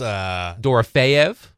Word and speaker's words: Dorofeev: Believe it's Dorofeev.